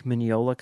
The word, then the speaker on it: Mignola: It's based on a Mike Mignola comic book, the guy who does Hellboy. – Is that how you pronounce his last name? – Yeah. Yeah, it is.